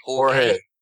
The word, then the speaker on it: Jorge: Now, it's by two guys, a guy called Patrick Cowley and a guy called, probably going to pronounce this incorrectly, Jorge? How would you pronounce that with the J? – Jorge. – There you go. Jorge.